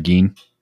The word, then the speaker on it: Gein: It's kind of like Ed Gein or whatever the hell, however you say his name. – Oh, Ed Gein.